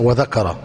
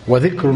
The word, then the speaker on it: وذكر: أشكل كلمة وذكر حتى لا تقرأها وذكر. وذكر وارد في أول العبادات،